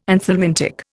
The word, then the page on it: anthelmintic: Indian